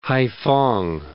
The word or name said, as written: Haiphong